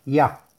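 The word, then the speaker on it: Ya (יה): Trae un nombre sagrado para meditar, que son las letras Yud Hei y se pronuncia Ya.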